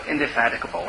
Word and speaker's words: indefatigable: Is it pronounced that way? No, indefatigable, right? Yeah, I thought so.